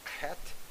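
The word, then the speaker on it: Chet (חית): It's the letter Chet. You have to clear your throat in these Hebrew syllables. Chet, which is the first word, first letter of the word Chaim.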